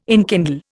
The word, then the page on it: enkindle: Indian